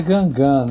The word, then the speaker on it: Guingamp: O jeito certo de falar é Guingamp, né? – É.